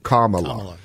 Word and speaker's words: Kamala: But what did Biden and what did Kamala – excuse me, you get in trouble if you don't pronounce this right – Kamala, like semicolon law, right. – comma law.